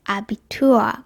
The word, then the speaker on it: Abitur: これは一番初めにアクセントがあるんですけど、 Abitur。これは Tour にアクセントがあるんですね。 Abenteuer。これは冒険。 Abitur っていうのは高校卒業資格っていう意味です。